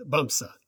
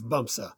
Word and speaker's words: Bamse: Bamsa. It's pronounced Bamsa. – Bamsa? – But it's spelled B-A-M-S-E.